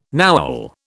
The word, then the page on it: Nowel: British English